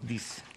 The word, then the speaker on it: disc: Del Compact Disc. – Disc. – Dix. En Paraguay. – No, espérese. Dix no es. D-I-S-C. Claro, disc.